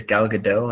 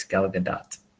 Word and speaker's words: Gal Gadot: I've been pronouncing it as Gal Gadot, and it's Gal Gadot.